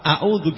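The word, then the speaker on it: A'udzu (أَعُوذُ): Nah, dia bacanya enggak a'u tapi a'u, alif sama alif, bukan alif sama ain, kan salah. A'udzu billahi minasyaitonir rajim.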